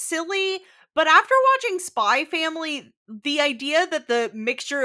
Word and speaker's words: Spy x Family: Well, you don't pronounce the X, it's just Spy Family.